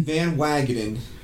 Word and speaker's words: Van Wagenen: Van Wagenen.